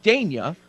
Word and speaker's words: Dania: I believe it's pronounced Dane Dania. – So, okay. So you've got the last name Dania, and we're going to call you Dane? Like, that's silly. – Dane Dania.